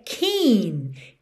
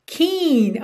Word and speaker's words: Keane: E atenção aí, já começamos a aula agora porque a pronúncia da banda é Keen. Keen, ok? Embora a escrita seja K-E-A-N-E, naturalmente soa aí em português Keane pra gente quando a gente lê a palavra, mas a pronúncia é Keen, alright?